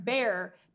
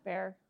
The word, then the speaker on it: Bexar: So it's pronounced bear, because with a Texas accent, if you try to say Behar, you're really just going to say bear.